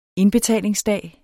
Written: indbetalingsdag

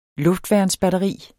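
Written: luftværnsbatteri

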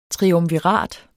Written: triumvirat

